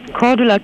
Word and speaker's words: Cordula: Cordula Kine.